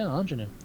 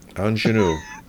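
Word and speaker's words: Aunjanue: I was going to correct you, then I looked like, no, yeah, Anjanue. – Anjanue. – It's not how it's spelled, but that's how it's spelled.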